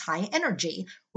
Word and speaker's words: high NRG: The music style is known as high NRG, pronounced high energy, which is post-disco that infused R&B, soul, and electronic dance music.